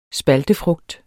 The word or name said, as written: spaltefrugt